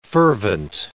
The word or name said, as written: fervent